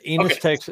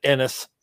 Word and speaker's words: Ennis: I think Texans pronounce it Ennis, Texas.